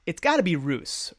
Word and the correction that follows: Ross: Roos Morales-Ricketto. Is that right? Oh, no, that's a typo. It's Ross. Ross Morales-Ricketto is now officially the first ever 2-time winner of Battery Mates preseason prediction contest.